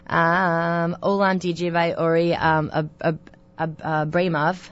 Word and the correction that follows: Uri Abramov: and I totally messed up his name. – Oh, Uri Abramov? – Oh, Abramov, there we go. – He's sorry. – Great. – Okay. I can't even pronounce English. Abramov, yes. Uri, yes.